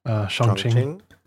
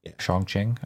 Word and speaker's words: Chongqing: Chongqing. – Chongqing.